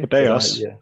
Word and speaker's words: Putellas: Putellas.